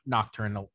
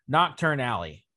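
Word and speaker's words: Knockturn Alley: Or not nocturnal, Nocturne Alley, not nocturnally.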